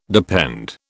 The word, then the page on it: depend: British English